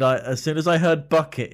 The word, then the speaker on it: Bucket: What flower, named for the lover of Apollo who it supposedly bloomed from, also gives its name to the bucket played by Patricia Routledge in Keeping Up Appearances? Although I'm told it's pronounced bouquet, darling.